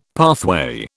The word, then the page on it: pathway: British English